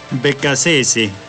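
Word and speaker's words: Beccacece: El entrenador Sebastián, ¿cómo se pronuncia? – Becasese. Porque es argentino.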